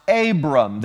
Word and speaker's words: Abram: And you're going to see Abraham pronounced Abram.